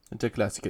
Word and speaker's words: Der Klassiker: Der Klassiker was about, did I say that right? – Der Klassiker, yeah.